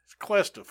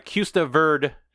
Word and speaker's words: Cuesta Verde: They live in the California housing development called Siesta Verde, which comes to be haunted by ghosts. – It's Cuesta, fool. You got to say it like a white guy. – Oh, by Cuesta Verde, which comes to be haunted by ghosts.